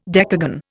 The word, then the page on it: decagon: Indian